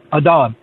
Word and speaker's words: Adom: It's Adam, A-D-O-M.